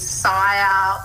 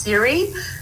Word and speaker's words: CIRE: So we do get people thinking it's Sire or all sorts of variations, but it is pronounced Siri. So any Apple users may have their phone pinging at them when they say it, but we are spelt differently with C-I-R-E.